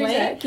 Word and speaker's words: Zusak: So I went Monday night after work, and I was lucky enough to hear Martin Zusak. I hope I'm pronouncing his name correctly. – Zusak, yes.